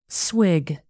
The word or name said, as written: swig